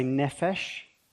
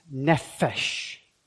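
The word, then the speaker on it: nefesh (נֶפֶשׁ): We're going to say nefesh, and we're going to say, and I want you to say it sort of really exaggerating the consonants, nefesh.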